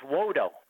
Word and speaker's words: Wodo: L-O-D-O? – No, it's Wodo. W-O-D-O.